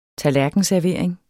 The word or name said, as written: tallerkenservering